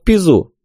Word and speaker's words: Пизу: Так, чтобы он ее в Неаполь вез и там в Пизу». – Ударение на первый слог.